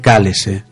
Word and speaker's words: cálice: de cando isto se pronuncia en portugués do Brasil, algo así como aparta de min este cálese.